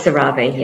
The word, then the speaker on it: CeraVe: It's CeraVe, yeah.